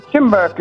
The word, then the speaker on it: Tymbark: Timbark is spelled T-Y-M-B-A-R-K.